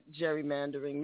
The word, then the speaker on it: gerrymandering: Always pronounced it gerrymandering. Maybe it's Gary. Yeah, so has half the free world. But it is, in fact, Gary.